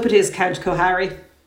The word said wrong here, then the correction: Kukuharu: It is pronounced Kukuharu. Do you doubt what I say? – Oh, no, sir. – Thank you. – Apologies. – Much better, much better.